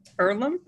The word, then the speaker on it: Earlham: She grew up in a small town, Earlham.